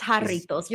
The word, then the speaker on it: Jarritos: and it's Jarritos. You're still saying it wrong. That's why I rolled my eyes. Cause we're literally like 3 years in and you still can't say it right.